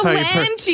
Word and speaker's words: Solange: That's Solange. Is that how you pronounce it? – Solange.